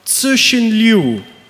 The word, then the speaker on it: Liu Cixin: In de Antwerpse Brouwcompagnie voor een Nerdland special podcast over The Three Body Problem, het boek van Tsushin Liu. Oké, ik heb het goed uitgesproken, eh, doet Frederik het tegen.